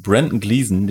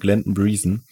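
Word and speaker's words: Brendan Gleeson: Aufmerksamen PodcasthörerInnen wird aufgefallen sein, Brandon Gleeson, den wir vorhin natürlich wieder falsch ausgesprochen haben, Glenton Breeson, kam schon mal vor bei uns im Podcast.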